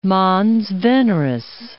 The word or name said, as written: mons veneris